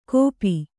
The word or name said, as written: ಕೋಪಿ